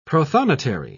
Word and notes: protonotary: British English

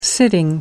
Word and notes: sitting: American English

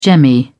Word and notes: jemmy: British English

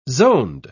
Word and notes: zoned: British English

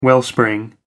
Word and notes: wellspring: American English

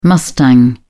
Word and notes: mustang: British English

